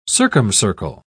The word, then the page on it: circumcircle: British English